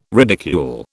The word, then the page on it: ridicule: British English